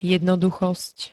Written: jednoduchosť

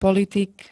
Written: politik

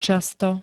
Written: často

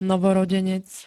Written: novorodenec

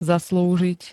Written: zaslúžiť

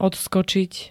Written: odskočiť

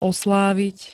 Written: oslavit

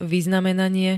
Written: vyznamenanie

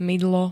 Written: mydlo

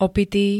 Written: opitý

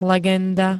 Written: legenda

